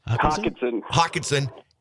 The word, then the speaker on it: Hockenson: You know, I got to tell you, I've always, like, I hated when the Lions had the tight end, Hockenstein. How do you pronounce his last name? – Hockenson.